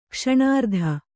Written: ಕ್ಷಣಾರ್ಧ

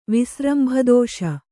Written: ವಿಸ್ರಂಭದೋಷ

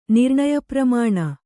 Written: ನಿರ್ಣಯಪ್ರಮಾಣ

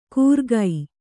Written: ಕೂರ್ಗೈ

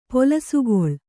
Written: ಪೊಲಸುಗೊಳ್

